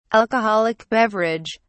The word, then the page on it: alcoholic beverage: British English